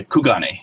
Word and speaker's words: Kugane: It is the one that's connected to Kirigane? Kiris? How do you pronounce that? One second. Yeah, Kugane. Yes, that's it.